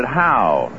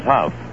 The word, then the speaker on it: Hough: They pronounced it Howe. H-O-U-G-H. They said Charlie Howe. There's Huff, like in rough.